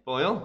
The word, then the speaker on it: Boyle: Boyle? – Yeah, Boyle. – Yeah, David Boyle, nice.